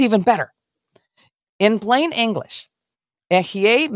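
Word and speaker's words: Ehye (אֶהְיֶה): We know this from Exodus 3:14, in which the Almighty explains his name as Ehye Asher Ehye, I am that I am, is how we pronounce, how we and have it interpreted in our Bibles.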